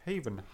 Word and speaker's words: Haven: Heaven. Also ich meine, es heißt. – Nee, Haven. H-A-V-E-N.